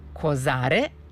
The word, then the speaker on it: Kozare: Znači, jug Srbije, kaže, videli smo fenomen, prelepe su fotografije, i kaže, jug Srbije, Kozare, sa akcentom na A, u blizini Grdelice, a onda smo imali, neko je pričao i o Kozaračkoj reci, ili je Kozaračka reka.